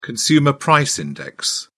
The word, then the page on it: consumer price index: British English